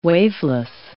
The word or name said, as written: waveless